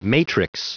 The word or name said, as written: matrix